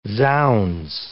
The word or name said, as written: zounds